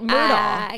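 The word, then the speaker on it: Murdaugh: So it's technically like, Murdoch. – I get it. – It's technically Murdoch. Everyone in the documentary said Murdoch, like literally Richard, Rupert, whatever. – I just did the same thing. I did the same thing. – Rupert's family, but it's spelled differently and it's Murdoch.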